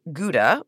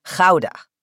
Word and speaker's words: Gouda: Of course, we say Gouda, but in the Netherlands, I've been working on my pronunciation. Here goes nothing. The cheese and the town are called Gouda.